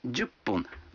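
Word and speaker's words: джупун (十分): А 10 минут – джупун. Вы заметили, что когда фун добавляется к 10, дю, то произносится пун.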